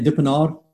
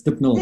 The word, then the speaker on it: Dippnall: Most people say Dippnall, but it's Dippnall from where I'm